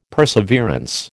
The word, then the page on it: perseverance: American English